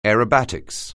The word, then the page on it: aerobatics: anglais